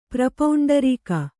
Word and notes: ಪ್ರಪೌಂಡರೀಕ: prapauṇḍarīka

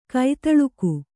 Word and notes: ಖತಿಲಕ: Kannada

